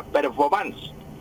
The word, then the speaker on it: performance: Esa es la pronunciación correcta de la palabra, performance.